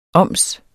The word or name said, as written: oms